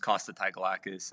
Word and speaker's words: Costa Tagalakis: My name is Costa Tagalakis.